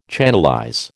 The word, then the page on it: channelize: American English